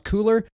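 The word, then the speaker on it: Cuellar: I think his last name is pronounced Cooler, C-U-E-L-L-E-R from Texas.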